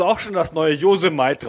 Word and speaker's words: Yosemite: Ich habe ja auch lange gebraucht, bis Apple die neue Betriebssystemversion rausgebracht hat, dass es nicht Yosemite, sondern Yosemite heißt. Diese Gegend da in Amerika.